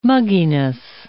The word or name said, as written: mugginess